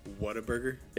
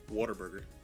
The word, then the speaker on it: Whataburger: So you know how people outside of Texas, they pronounce it Whataburger? – Yeah. – Just like how it's spelled. – Whataburger. – Whataburger. In Texas, because they have that draw, it's Whataburger. – Whataburger? – Yeah. So it's like it's like they're saying water.